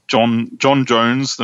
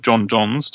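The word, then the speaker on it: J'onn J'onzz: But Batman and John Stewart, the Green Lantern, and John Jones, the Martian Manhunter, or John Johns, depending on how you say it.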